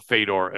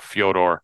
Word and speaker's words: Fyodor: Fedor, I even throw Fedor Emelianenko in there, or Fyodor as they pronounce it.